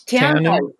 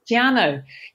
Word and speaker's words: Te Anau: Yeah, I found out it was Te Anau. – Oh, it's Tiano. Tiano. Yep. – Tiano. Yes. Okay. – It's a Maori word, so it's quite hard to pronounce.